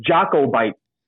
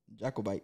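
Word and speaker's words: Jacobite: And then I was like, oh, it's Jocko Bite. – J-A-C-O-B-I-T. Yeah. Jocko Bite.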